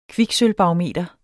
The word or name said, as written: barometer